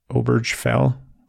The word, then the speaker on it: Obergefell: Obergefell versus yeah.